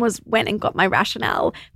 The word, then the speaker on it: Rationale: R-A-T-I-O-N-A-L-E, Rationale.